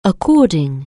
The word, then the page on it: according: anglais